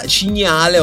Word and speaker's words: Cignale: Rimanendo nel Lazio, in quel di Canale Monterano, che sta in provincia di Roma, Cignale, ho detto bene perché li dicono così, in realtà è la Sagra del Cinghiale,